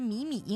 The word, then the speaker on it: 靡靡: 这个里边的靡靡应该读作的是三声，它形容事物柔弱萎靡不振这样的样子啊。萎靡不振，萎靡不振啊。 – 而这个靡字啊，就是很多常见的我们的读音都给它读错了，读成二声了啊。